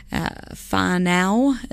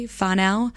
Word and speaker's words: Whānau: And you bring up a good opportunity for me to correct myself, actually. Whānau is the correct way to say that. Family in Māori. Whānau. My sister corrected me.